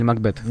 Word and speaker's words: Макбет: И «Трон в крови» — это такая вольная экранизация Макбета. Надеюсь, я правильно ударение поставил. – Макбет, да. – Макбет или Макбет? – Макбет. – Макбет, Шекспира.